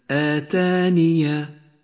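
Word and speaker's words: آتانِ: آتانية آتانية هناك ياء غير مكتوبة في آخر الكلمة أشير لها بياء صغيرة مفتوحة في آخر الكلمة هي غير مكتوبة ولكنها تلفظ أي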